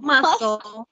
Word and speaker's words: muscle: So she, Gypsy Rose, went under a muscle, my goodness, a muscle. – Muscle. I don't know why I keep saying muscle. So let me move my laptop closer. – That's that silent C.